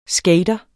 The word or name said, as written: skater